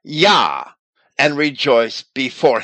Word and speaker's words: Yah: And Strong's goes on to say that it means praise ye Yah, which he spells with a J, J-A-H. Spelling it with a J rather than a Y does not change the proper pronunciation.